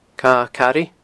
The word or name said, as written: Cadha Cairidh